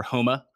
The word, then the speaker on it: Homa: Max Homa is another one, or Homa, because the PGA Tour corrected me on that pronunciation when I was doing videos for them.